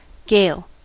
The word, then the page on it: gale: American English